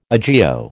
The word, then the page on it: agio: American English